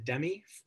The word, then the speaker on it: Demme: He actually lost to Jonathan, is it Demme, from Silence of the Lambs, is how you pronounce it? – Yeah.